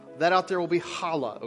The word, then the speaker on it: challah: Some of our ladies got together, my wife and some others, and they baked some challah bread. Now, challah bread, okay? That's how you say it. Challah. I can't get the name, but it's pretty close.